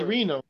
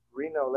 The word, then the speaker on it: Renault: What's Renault? – The dealership Reno.